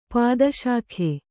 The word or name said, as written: ಪಾದಶಾಖೆ